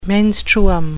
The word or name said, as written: menstruum